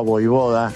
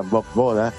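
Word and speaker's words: Vojvoda: Lo veo a Voivoda, que así se debe pronunciar, y no como la mayoría lo pronuncia Bob Boda.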